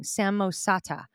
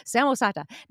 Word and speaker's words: Samosata: I always say that wrong. Samosata. Samosata.